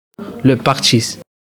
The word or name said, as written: partchis